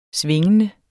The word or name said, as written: svingende